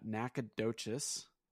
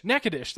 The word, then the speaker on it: Nacogdoches: Okay, so there's Here's how do locals pronounce it? Uh, Nacogdoches. Nacogdoches. Nakadish. – That's what it was. It was Nakadish. – Yeah, there's Nakadish or Nacogdoches, but I think okay. most of them pronounce Nakadish.